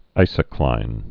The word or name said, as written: isocline